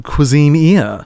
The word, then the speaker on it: Cuisineer: Well, this is a little game called Cuisineer, which is how I choose to pronounce it. I've heard others say Cuisineer. I'm like, that is wrong. I like Buccaneer, but with cuisine at the front.